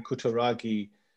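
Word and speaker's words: Kutaragi: Did I pronounce his name correctly? Ken Kuturagi, father. Ah, nice.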